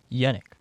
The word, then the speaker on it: Yenik: Yenik. – Yenik. How do you spell that? – Y-E-N-I-K. – Y-E-N-I-K.